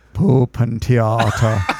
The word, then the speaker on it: Puppentheater: The way they say puppet theater, they say Puppentheater.